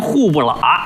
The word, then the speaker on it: 虎伯劳: 哎，老北京本地发音户不喇。哎，这写出来呀叫伯劳啊，老虎的虎，虎伯劳，念出来户不喇。